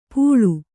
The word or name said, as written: ಪುಳು